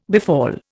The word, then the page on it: befall: Indian